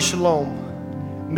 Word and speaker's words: shalom: That word shalom means to stop that which causes chaos.